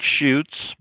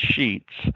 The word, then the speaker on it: Schutz: Sheets is S-C-H-U-T-Z. It looks like shoots, but they pronounce it as Sheets.